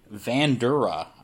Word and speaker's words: Vandura: a 1983 GMC Vandura. I don't know if I said that right. V-A-N-D-U-R-A.